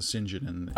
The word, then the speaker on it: St. John: I think, like, yeah, they pronounce it St. John in Merry Old England.